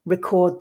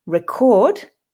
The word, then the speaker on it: record: For example, in this week's title, Record the Record, record, the verb, is stressed on the second syllable, record, but record, noun, is stressed on the first.